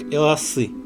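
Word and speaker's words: эласы (俄罗斯): Например, Россия китайцы произносят как эласы – и есть такая байка, что это эласы можно записать такими иероглифами, что получится нечто вроде страны голодных.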